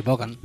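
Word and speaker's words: Vaughan: Y bueno, la verdad, yo decía, a mí esto me gusta más que él, que Steve Ray Buganes. ¿Bogan? ¿Bogan? – Sí, Steve Ray Bogan. – Bogan. – Lo pronuncio así alemán, Bogan.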